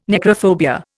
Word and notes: necrophobia: Indian

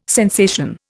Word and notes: sensation: Indian

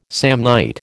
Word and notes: Samnite: American English

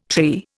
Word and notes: trey: Indian

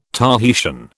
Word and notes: Tahitian: British English

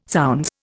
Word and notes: zounds: Indian

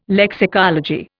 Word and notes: lexicology: Indian

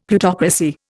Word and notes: Plutocracy: Indian

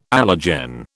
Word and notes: allergen: British English

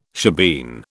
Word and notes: shebeen: British English

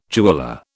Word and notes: jeweller: British English

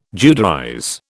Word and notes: Judaize: British English

